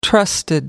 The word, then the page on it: trusted: British English